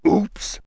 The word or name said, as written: oops